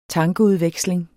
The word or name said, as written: tankeudveksling